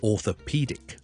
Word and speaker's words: orthopedic: 骨科咧，我哋叫做 orthopedic，呢个相对容易嘅 orthopedic。 author 其实听落去咧系同作家一样，author 一样发音。跟住 pedic，orthopedic。咁呢个就系骨科。